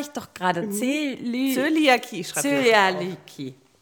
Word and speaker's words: Zöliakie: Ich habe Zöliakie, bitte gewöhn dir das an. – Das ja, ich kann es nicht, kann ich aussprechen. Zöliakie. – Zöliakie.